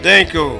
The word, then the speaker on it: Shankle: Eloise Danko. – How do you spell that last name? – S-H-A-N-K-L-E.